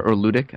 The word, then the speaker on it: ludic: The first word is ludic, or ludic, actually, is how to pronounce it, from Latin, which means to play.